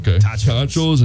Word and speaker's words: tachos: Angie and Jason have a knack for delicious up north cuisine, like their tarachos. Am I saying that right? – Tachos.